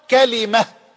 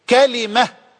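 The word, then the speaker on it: كَلِمة: اللغة الأولى قال كلمة، كلمة بفتح الكاف وكسر اللام، وسامحوني على هذا التأصيل، فسننطلق منه بعد ذلك إلى لقاءات عديدة إن شاء الله جل وعلا.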